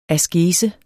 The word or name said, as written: askese